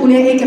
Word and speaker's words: أولئك: زي أولئك كده يا اخوات، كلمة أولئك في كل المصحف على الواو اللي بعد الهمزة ايه؟ دايرة. ليه الواو دي ما تنطقش؟ هقولك لو كانت متنطق ما كانتش هتتنطق أولئك، كانت هتتنطق أولئك، لأن احنا حرف مد لكن هي ليست حرف مد.